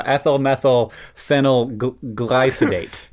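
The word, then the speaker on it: ethyl methylphenylglycidate: Today's podcast is brought to you by ethyl methyl glycyl rye candy. What is it called? What'd you call it? – I don't even think I said it right. Ethyl methyl phenyl glycidate.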